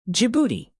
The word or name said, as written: djibouti